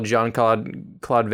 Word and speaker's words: Jean-Claude: Because I got told off because we accidentally, apparently I called Jean-Claude Van Damme Gene.